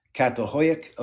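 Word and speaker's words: Çatalhöyük: It is the site of, I'm going to spell it and then I'll pronounce it, C-A-T-A-L-H-O-Y-U-K. That's Catalhoyuk.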